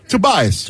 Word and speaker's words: Tobias: I totally like the acquisition of is it Tobias, or how do you pronounce his first name? – Tobias. – Tobias Harris.